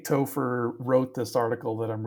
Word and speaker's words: Topher: Y'all know that one of the top 10 baby names of 1978 was Topher. – Topher? – Topher. – Yes. – How do you spell that? – T-O-P-H-E-R, like gopher with a T.